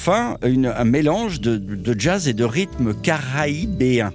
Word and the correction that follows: caribéen: L'ai-je bien prononcé, ma chère Victor Caribéen.